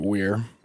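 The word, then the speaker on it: Weiher: Their names are, or were, I guess, Jack Hewitt, Gary Mathias, William Sterling, Jack Madruga, and Theodore, is it Weir? – I pronounced it Weir. – Okay. It's W-E-I-H-E-R. Weir, yeah. I guess.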